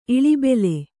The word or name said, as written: ಇಳಿಬೆಲೆ